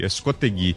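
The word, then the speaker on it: Escosteguy: Acho que eu tô errando a pronúncia do sobrenome dele. Escostegui, né? Isso aí, Escostegui.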